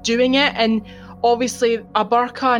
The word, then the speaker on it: burqa: is that how you pronounce it? Uh, yeah, hijab, uh, burqa.